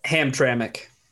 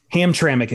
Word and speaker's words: Hamtramck: Hamtrak. Am I saying that right? I can never say Hamtrak's right. A name, right? – Hamtramck. – Hamtramck, excuse me.